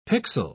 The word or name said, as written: pixel